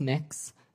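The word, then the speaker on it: Mx: It's pronounced mix.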